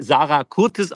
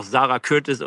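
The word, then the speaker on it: Sara Curtis: Und sie hatte, entschuldige, dass ich korrigiere, ich wusste das auch nicht im Vorfeld gesagt, dass sie Sarah Curtis ausgesprochen wird. Es wäre aber völlig okay, wenn man auch Sarah Curtis oder Sarah Curtis sagt.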